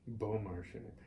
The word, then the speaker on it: Beaumarchais: Because you're actually saying it correctly, and it's not difficult. Beaumarchais.